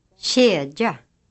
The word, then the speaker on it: kedja: K is pronounced like a light ch, a sound similar to the sound in English birch, inch, etc. Listen to the examples. – Kedja.